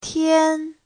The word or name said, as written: tian1